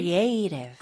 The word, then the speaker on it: creative: Creative. 강세는 A 해주세요. – Okay. – Creative.